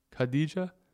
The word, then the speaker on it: Khadijah: K-H-A-D-I-J-A-H. How do you think that's pronounced? – Wait, spell it again. – I think it's Khadija. Khadija? Mustafa. – Yeah, I was going to say Khadija.